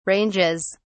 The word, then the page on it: ranges: British English